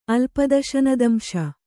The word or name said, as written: ಅಲ್ಪದಶನದಂಶ